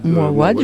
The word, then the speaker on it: Mouawad: Alors voilà, je pense que c'est le texte de Wajdi Mouawad, je ne sais pas comment on le prononce. – Mouawad,